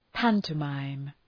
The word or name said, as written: pantomime